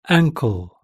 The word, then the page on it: ankle: British English